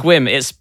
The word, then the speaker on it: Sequim: Squim. It's spelled S-E-Q-U-I-M, but then there is a pronunciation in brackets afterwards.